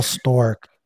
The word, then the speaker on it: Sztorc: Paul Stork. It's spelled S-Z-T-O-R-C.